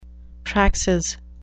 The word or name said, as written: praxis